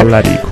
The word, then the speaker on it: Tallarico: Sì, decisamente sì, perché non so se è più difficile trovare l'accento a Tallarico o Tallarico che a Steven Tyler. – Tallarico.